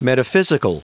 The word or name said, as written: metaphysical